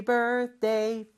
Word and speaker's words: birthday: Birthday에 TH에서 D로 넘어가는 게 조금 어려워요.